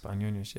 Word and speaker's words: Panionios: Panionios, yes. – In Greece, is that correctly pronounced? – Correct, exactly, yeah.